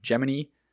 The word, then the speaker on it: Gemini: I don't know why, but NASA had decided that the official pronunciation would be Project Gemini, even though it seems like the general public generally would pronounce it Gemini. Unfortunately, I'll probably interchange those on accident because I'm so used to saying Gemini, but I'm going to attempt to use the official Gemini pronunciation.